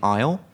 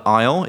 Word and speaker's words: aisle: I quite like the expression to walk down the aisle. – Mm-hmm. – So the aisle is spelt ASLE. So it's not, doesn't sound like it looks.